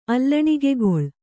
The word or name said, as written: ಅಲ್ಲಣಿಗೆಗೊಳ್